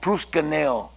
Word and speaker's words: proskuneo: It's the word proskuneo. That's how you say it in Greek.